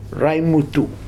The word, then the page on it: raimutu: Futuna